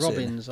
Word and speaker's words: Robeyns: Robbins, I'd say. – Robbins, yeah.